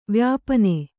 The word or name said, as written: ವ್ಯಾಪನೆ